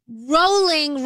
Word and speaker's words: rolling: Oh, FYI, rolling rhymes with bowling, not Rowling, JK Rowling.